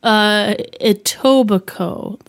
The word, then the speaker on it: Etobicoke: Etobicoke. That's spelled... – Etobicoke. – Yeah. E-T-O-B-I-C-O-K-E.